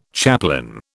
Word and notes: chaplain: British English

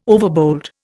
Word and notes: overbold: Indian

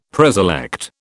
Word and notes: preselect: British English

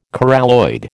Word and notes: coralloid: American English

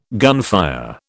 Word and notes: gunfire: British English

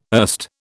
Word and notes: erst: British English

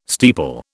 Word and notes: steeple: British English

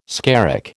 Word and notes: skerrick: American English

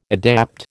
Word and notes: adapt: American English

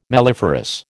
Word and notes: melliferous: American English

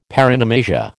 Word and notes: paronomasia: American English

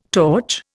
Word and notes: torch: Indian

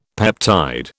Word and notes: peptide: British English

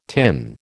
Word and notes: tin: American English

